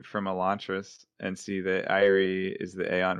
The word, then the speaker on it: IRE: So this organization, it's spelled IRE, and three letters, IRE. IRE is pronounced Irie.